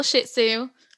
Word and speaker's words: Shih Tzu: He's a little Shih Tzu. Emphasis on the first syllable of that. he's adorable.